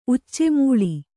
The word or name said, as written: ಉಚ್ಚೆಮುೂಳಿ